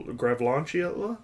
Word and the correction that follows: Galvantula: Galvantula? – Yeah, whatever the fuck.